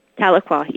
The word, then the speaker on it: Tahlequah: And then I think the final one that we haven't talked about was supplied by Melissa Robbins of, how do you say this, Tahlequah? – Tahlequah, yes.